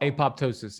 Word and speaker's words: apoptosis: And that concept of autophagy and apoptosis, have I said that right? – Apoptosis, yeah.